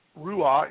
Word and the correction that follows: Ruach: And it's actually Ruach. It's a CH sound at the end of that, even though there's no C in the word. It's Ruach, Ruach HaKodesh.